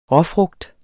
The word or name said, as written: råfrugt